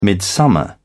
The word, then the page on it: midsummer: British English